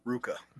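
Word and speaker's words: RVCA: Wait, how do you pronounce it? – Ruka. – That does not say fucking Ruka. There's no U in there. – Doesn't need to be, man. – V does not sound like U, and U does not sound like V. – Hey, man, that's the way they pronounce it.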